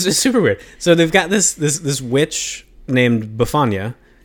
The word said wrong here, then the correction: Befana: No, Befana.